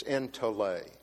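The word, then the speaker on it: entole (ἐντολή): And the Greek pronunciation is entole.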